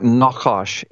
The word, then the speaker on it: Nachash: It's a, yeah, you asked how it's pronounced uh, in in the Hebrew. It'd be it would be more like Nachash, N A W Nachash.